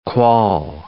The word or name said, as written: quoll